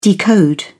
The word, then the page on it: decode: British English